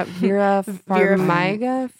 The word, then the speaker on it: Vera Farmiga: And I think that the performances by, how did you say it? Vera Farmiga? – Yeah, Vera Farmiga.